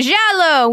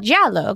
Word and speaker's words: giallo: which is why it's called giallo, because I've taken exactly... – Wait, can you spell that? – Yeah, it's G-I-A-L-L-O. – Oh, okay. – Which okay. – is you might recognize as the Italian word for yellow.